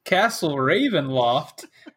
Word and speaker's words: Ravenloft: And the last thing we said, you passed by a road that seemed to point towards Cassin Ravel Bluff? Castle. – No, that's one of my favorite. You said it wrong. – Castle Ravenloft, which is pop quiz.